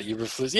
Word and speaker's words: Eberflus: I have no idea how to say it. Eberflus? – Oh, Matt Eberflus.